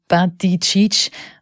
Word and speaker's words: Buttigieg: Der 39-jährige Verkehrsminister Pete Buttigieg, ausgesprochen Buttigieg, und sein Ehemann Jason haben im Sommer neugeborene Zwillinge adoptiert.